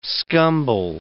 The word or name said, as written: scumble